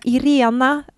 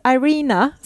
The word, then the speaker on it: Irena: Och så tänker jag, Irena, kanske de tycker det är så svårt att uttala. Så jag tänker så här, om jag försöker göra det lite så här swenglish, så kanske de uppfattar det rätt. Så jag bara, well, my name is Irina, säger jag.